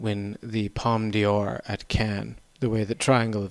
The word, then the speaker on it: Cannes: At Cannes. Sorry, Cannes. – Cannes. – Is that how you say it? – Yeah.